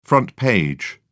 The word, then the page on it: front page: British English